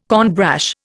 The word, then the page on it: cornbrash: Indian